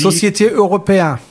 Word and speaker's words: Société Européenne: Die einzige Gesellschaftsform, die dir erlauben würde, quasi einen europäischen Binnenmarkt sinnvoll zu bespielen, ist eine SE, also eine Societas Européenne, ich weiß nicht, garantiert falsch ausgesprochen. – Und die Société Européenne. – Ah ja, perfekt.